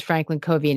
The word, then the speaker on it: Franklin Covey: I mispronounced it, by the way. It is Franklin Covey and it's FC is the ticker and it trades on the New York Stock Exchange.